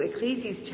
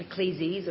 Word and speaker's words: Ecclesiastes: So if you'd like to keep your Bibles open to Ecclesiastes chapter 1, I should pronounce it correctly, not Ecclesiastes or Ecclesiastics, and the booklet has the outline of what I'm going to say, so you might want to follow on there as well.